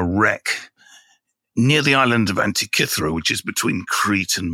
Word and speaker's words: Antikythera: Firstly, let me say, it's the Antikythera mechanism. It's not the Antikythera mechanism. That's how they pronounce it in the film, you know. They spent $295 million making it, and they couldn't bother to ask a Greek person how to pronounce it. It's pronounced Antikythera.